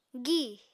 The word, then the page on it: gi: Apatani